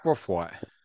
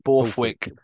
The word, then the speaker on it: Borthwick: Steve Brothwaite? – Okay, that is the worst pronunciation of Steve. – What's his name? – Borthwick.